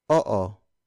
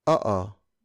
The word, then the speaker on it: oo: Oo. Oo. It's spelled as o-o. Notice that in Tagalog words with double vowels like oo, we pronounce the vowel separately. So it's not oo. It's oo. Again, oo. Oo.